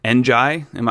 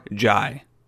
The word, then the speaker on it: Njie: No, it is Babacar Jai. No N, although there is an N, which is the first letter of his last name, but it's just Jai.